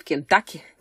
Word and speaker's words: Кентукки: Я когда-то была в Кентукки, в Кентаки, конечно, правильно говорить, и на завтрак мне было очень сложно найти какую-то еду там вообще, в принципе, потому что там либо эти KFC...